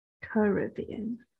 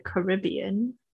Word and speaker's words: Caribbean: Caribbean.